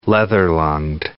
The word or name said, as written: leather-lunged